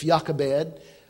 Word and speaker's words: Jochebed: It's spelled with a J. I'll spell it for you. Actually, it's Jochebed. But in the Hebrew language, they didn't pronounce the J. They used it as a Y, a Yah sound.